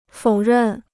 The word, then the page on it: 否认: fǒu rèn